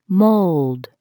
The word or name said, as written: mold